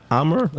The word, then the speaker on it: Amager: Amager. That's how you say it. A-M-A-G-E-R.